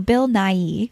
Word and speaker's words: Bill Nighy: And so Bill Nighy, is that how you say his name? – Yeah.